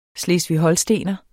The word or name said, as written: HOLSTENER